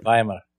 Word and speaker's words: Weimar: And the Weimar, how do you say it? – Weimar.